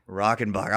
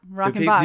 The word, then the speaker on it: Rockenbach: Rockenbach. – I was going to say Rockenbach. Yeah. – Yep, Rockenbach.